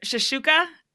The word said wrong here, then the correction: shakshuka: Oh, shakshuka.